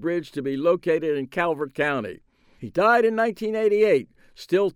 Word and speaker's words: Calvert: Louis was comptroller of Maryland and an unapologetic, unrepentant booster of Calvert County. He pronounced it Culvert County, where he was born and raised and developed his political muscle.